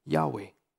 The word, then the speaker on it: Yahweh: It is the divine name of God, known as YHWH, which we pronounce, although respectfully, Yahweh.